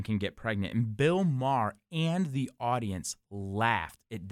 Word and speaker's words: Maher: I always say his name wrong. – I think it's Maher. – Maher, is it right? – I think okay.